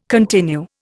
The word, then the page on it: continue: Indian